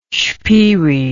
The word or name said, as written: spyri